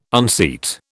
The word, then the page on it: unseat: British English